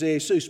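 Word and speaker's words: Iesus (Ἰησοῦς): And so the Greek pronunciation is Iesus.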